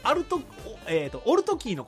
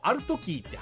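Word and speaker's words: Altキー: で、友人がね、オルトキーのことをアルトキーって発音するので、オルトキーって言うんだよって教えてあげたら、キレられた。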